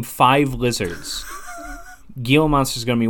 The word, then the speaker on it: Gila: Let's talk Gila monsters. Or Gila. – My friend, it's pronounced Gila. Gila monster.